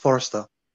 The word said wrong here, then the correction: Forster: Say it right. – Fraser Forster. – It's a long R.